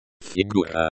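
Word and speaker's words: фигура: Figura. Figura, the U sounds like the U in moon.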